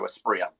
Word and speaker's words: Asprilla: The double L equals a Y in Spanish, so Aspria.